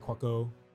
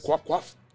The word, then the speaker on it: Cuoco: Kaylee Cuoco. – That's apparently, I've been pronouncing her name wrong for years.